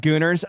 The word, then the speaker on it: Gooners: To the Gooners. – Yeah, right? – To the Goners. Thank you for listening. I hope you don't pay for this podcast. – It's pronounced Gooners.